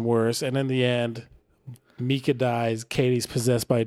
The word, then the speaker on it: Micah: His name is Mika, but it's spelled Micah. – Mika and Katie.